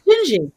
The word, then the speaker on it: Dindi: I probably didn't say it correctly, but it's Jinji. It's actually spelled D-I-N-D-I, and it's Portuguese.